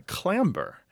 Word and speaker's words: clamber: As John Wells points out, there are some other words that are related etymologically where we still pronounce the B. So, for example, climb is related to clamber.